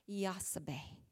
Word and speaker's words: Jochebed: In the Hebrew, actually, Yah is one of the syllables for God, Yahweh, and apparently that is one of the syllables in her name, Yah. So it's probably more like Yahsabeh.